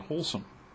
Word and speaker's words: Holsum: I think of a brand name for bread that I recall having seen years ago, H-O-L-S-U-M. And of course, when you pronounce H-O-L-S-U-M, you get wholesome.